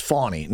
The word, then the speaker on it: Fani: Now, that's Fani Willis, uh, who, says no, her name is pronounced Fawny. No, it, it's Fannie.